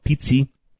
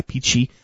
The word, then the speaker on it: pici: The other thing is they have this, it's called Pizzi, P-I-C-I, Pizzi. I always kind of say that wrong.